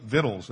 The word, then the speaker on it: victuals: So the people took victuals, and that is the way that word's pronounced.